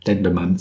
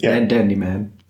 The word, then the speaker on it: Dendemann: Ja, Dendemann. Ist nicht Dandyman.